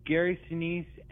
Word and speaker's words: Sinise: I think it's Gary Sinise. Is that, am I saying his name right? He was Gary Sinise, and I'm guessing you're talking about the house.